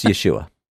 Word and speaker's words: Yeshua: And there's a lot of other pronunciations of it out there, and they all have reasons for it and all that. And I'm just going to say, please don't. It's Yeshua.